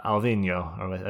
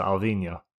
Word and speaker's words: Alvinho: And he's uh, Portuguese, so it is uh, Alvinho, I believe. So Alvinho, and that's probably the closest I'm going to get.